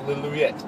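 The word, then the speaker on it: Lillooet: It's Lilouette. – Lilouette. – Lilouette. – That's the, of course, pronunciation by our local resident.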